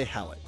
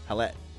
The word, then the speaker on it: Hallett: I thought it would be remiss of us not to mention that this past week, Andy Hallett, I'm going to say, or Halle. – How you spell it? – H-A-L-L-E-T, but I think there's a little funky thing over the E. – I say Hallett.